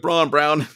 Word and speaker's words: Braun: You see Braun, like Braun Brown.